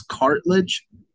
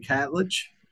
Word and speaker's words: Catledge: I think I've had him on the podcast before, and I mispronounced his name as Cartledge. So I'm glad I was able to redeem myself with Terry Catledge.